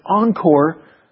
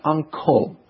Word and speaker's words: encore: It's encore. When there's a big crowd of people saying encore, sometimes here it seems like they're saying the word uncle. – Oh. – Uncle. And it makes me want to say aunt. I love both my uncle and my aunt. So friends, it is encore, not uncle.